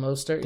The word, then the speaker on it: Mostert: Uh, like guys like I'm going to say this wrong, Raheem Mostert. Raheem Mostert. – Did yeah. I say that correctly? yep.